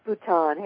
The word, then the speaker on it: Bhutan: Is it pronounced Bhutan? – It is Bhutan.